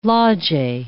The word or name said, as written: loggie